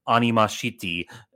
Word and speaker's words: Anima City: That's pronounced Animashiti, and then it also sounds like Animosity, and I'm like, very, very clever, very subtle.